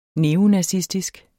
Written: neofyt

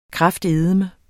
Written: kraftedeme

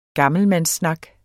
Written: gammelmandssnak

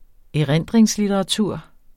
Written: erindringslitteratur

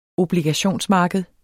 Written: obligationsmarked

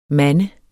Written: mande

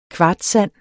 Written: kvartssand

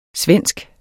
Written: svenske

